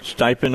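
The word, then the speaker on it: Stepien: Is it Bill Stiepen? Is that the way you pronounce his name? – Mm-hmm. – Stiepen, okay. As more and more polls are showing Joe Biden opening up a significant early lead in the race for the White House.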